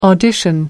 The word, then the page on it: audition: American English